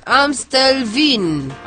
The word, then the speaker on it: Amstelveen: Amstelveen.